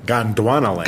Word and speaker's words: Gondwanaland: And it'll be all about, I'm going to say it wrong, Gondwanaland? – Gondwanaland. – Gondwanaland. I forgot a D.